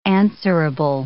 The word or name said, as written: answerable